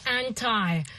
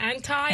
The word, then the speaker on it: anti: So the first one we've chosen is anti, anti or anti. – Right. – How do you say that? Anti, anti. anti? Either one is okay.